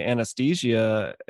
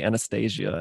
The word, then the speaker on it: anesthesia: But after the fact, she tells me that like they had issues with the anesthesia. For some reason, my brain wants to say Anastasia, and I know that it's not what it's called.